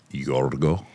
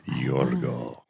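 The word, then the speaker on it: Yorgo (Γιώργο): Do people know that the Greeks pronounce George Yorgo? Yorgo.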